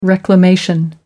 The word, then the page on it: reclamation: American English